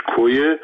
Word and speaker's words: کویه: در کردستان عراق در شهر کوی سنجد یا به اصطلاح تلفظ کردیش کویه جلوی در ورودی ساختمان مرکزی حزب دموکرات کردستان.